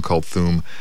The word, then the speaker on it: Kulthum: It was spelled and pronounced many, many ways, but we'll go by Kulthum.